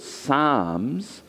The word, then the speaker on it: Psalms: which is called Psalms. It starts with a silent P.